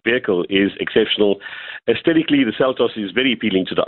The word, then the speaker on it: Seltos: Well, the car you've been driving around on, is Kia, is it Seltos? Is that how you pronounce it? – Seltos, that's the way you pronounce it.